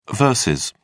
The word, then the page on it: vs: British English